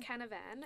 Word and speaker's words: Canavan: Canavan, is that how you say his name? – Matt Canavan, yep.